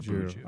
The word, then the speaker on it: BJU: So if you try and pronounce my initials, BJU, kind of sounds like Buju.